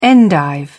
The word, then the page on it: endive: British English